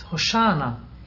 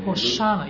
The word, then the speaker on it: Hosanna: Why do you pronounce Hosanna? It's Hosanna. – Yeah, I heard you doing it. – Hosanna. – Hosanna. – Hosanna, yeah. It's Hebrew. – Yeah. – It's Hebrew. Hosanna.